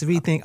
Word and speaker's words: Riithink: Yes, Rethink, R-I-I-T-H-I-N-K. – Spell that again, R-I-I-T-H-I-N-K.